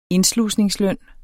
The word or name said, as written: indslusningsløn